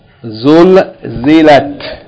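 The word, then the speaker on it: Zulzilat (زلزلت): Zul zilat. Three syllables. The first syllable is zay, dhamma, lam with sukoon. Zul. Second syllable, zay with kasra, z. And the third syllable is lam with fatta, ta with sukoon. Lat. Zul zilat.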